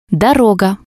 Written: doroga

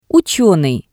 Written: utsenii

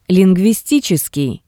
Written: Linguistitseskii

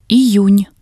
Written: iyun

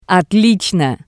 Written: Otlitsno

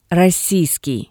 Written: rossiiskii

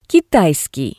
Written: kitaiskii

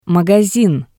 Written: magasin

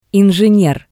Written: ingener

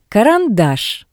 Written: Karandash

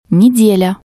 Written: nedelya

